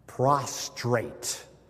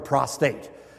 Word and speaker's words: prostrate: And I'm reminding him to be careful how to pronounce the word. Prostrate, right? Prostrate. If I mispronounce it, I'm referring to something else, right? A male organ, prostate. It's prostrate.